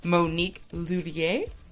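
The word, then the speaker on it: Monique Lhuillier: Her dress was designed by her good friend Monique Lullier. Yeah, that's the way to say it. I always say her name wrong.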